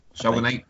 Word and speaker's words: Shogunate: And what happens is the Shogun Knight, is that how you say it? – Shogunate.